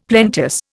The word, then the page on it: plenteous: Indian